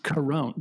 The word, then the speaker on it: Carone: And Melissa, and I found out her name is pronounced Carone, just like coronavirus.